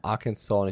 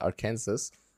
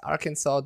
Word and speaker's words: Arkansas: Die offizielle Sprach Aussprache äh, heißt Arkansas, denn 1881 hat man das gesetzlich geregelt, nachdem sich zwei Senatoren über die Aussprache ihres Bundesstaates stritten.